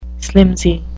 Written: slimsy

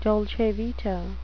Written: dolce vita

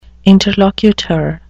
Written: interlocutor

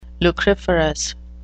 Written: lucriferous

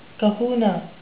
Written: kahuna

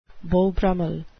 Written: beau brummell

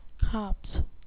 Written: copse

